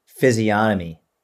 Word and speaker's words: physiognomy: Physiognomy. – It's got a G in there, right? – Yeah, but the G is silent apparently.